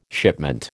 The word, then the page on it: shipment: American English